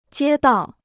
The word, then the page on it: 街道: jiē dào